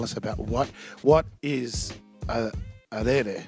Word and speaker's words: raerae: So you say it's spelled R-A-E, R-A-E, but it's pronounced rere.